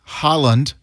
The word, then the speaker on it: Haaland: Erling Haaland, H-A-A-L-A-N-D, he's with Norway, and he is not only part of the team, but he's become pretty distinguished.